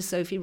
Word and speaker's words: Sophy: It was to Sophy Roberts.